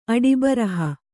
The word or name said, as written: ಅಡಿಬರಹ